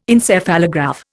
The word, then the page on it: Encephalograph: Indian